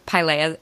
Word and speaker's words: Pilea: Pie, like, you know, apple pie, mm-hmm. and Leah, like the name Leah. So Pilea peperomioides. – Peperomioides. – Yeah, mioides. – Mioides. Pilea peperomioides.